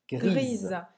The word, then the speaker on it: gris: Grisa. Gris E é muito. – Gris, você não vai pronunciar o S e grisa.